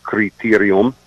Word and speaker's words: criterium: So this is officially a criterium, and the Italians use that word also. They pronounce it, of course, criterium.